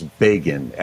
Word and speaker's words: Begin: His pseudonym it was spelled like the word begin, but it was French, and it was Begin, Al Begin.